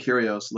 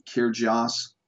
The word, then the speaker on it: Kyrgios: And so Nick Kyrgios looks like Kyrgios, pronounced Kyrgios.